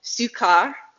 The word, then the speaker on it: Sychar: So he came to a town in Samaria called Sychar, that's how you say it in the Greek, near the plot of ground Jacob had given to his son Joseph.